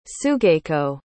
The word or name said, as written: sugako